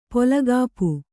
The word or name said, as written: ಪೊಲಗಾಪು